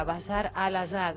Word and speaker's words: Bashar al-Assad: Bueno, algo parecido ocurrió en Siria, donde un grupo de jóvenes decidió rebelarse contra el poder, contra Bashar al-Assad, no sé si lo pronuncié bien.